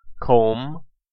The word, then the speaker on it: comb: The letter B is usually silent at the end of words in the combination MB, in words of Old English origin. Examples of these words include comb, crumb, dumb, lamb, numb, and womb.